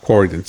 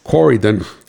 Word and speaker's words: Corydon: If you were driving west on 2, you would have gone through the small town of Croydon, Corydon, I think it's pronounced Corydon, C-O-R-Y-D-O-N, I always pronounce it wrong. Corydon, East Penn, who manufactures Deca batteries, has a large facility there right next to the highway.